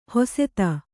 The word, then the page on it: ಹೊಸೆತ: hoseta